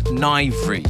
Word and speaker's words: Nwyvre: This is a wonderful little track called Social Mobility by, I think you pronounce it as Nivri or Nivri. It's spelled N-W-Y-V-R-E. That's, I believe, Welsh.